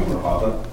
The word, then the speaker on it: Grijalva: My name is Samuel Grijalva. Last name is spelled G-R-I-J-A-L-V-A.